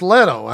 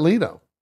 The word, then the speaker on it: Leto: No, it's Leto. It's not Leto.